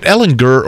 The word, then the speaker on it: Ehlinger: Is it Ellinger or Ellinger? Gurr, hard hard G.